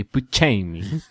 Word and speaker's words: Buscemi: You've been pronouncing his name wrong your whole life. It's actually Buscemi.